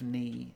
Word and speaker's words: knee: And as we said in, like, the silent letters episode, the K of knee is silent now, but it wasn't always silent.